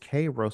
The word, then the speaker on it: Cairo: I'm in Cairo. They said, no, it's Cairo.